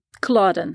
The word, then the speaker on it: Culloden: He prophesied about the Battle of Culloden. Did I say that right? – Culloden.